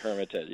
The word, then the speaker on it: Hermitage: Yes, Hermitage, yes.